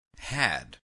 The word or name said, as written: had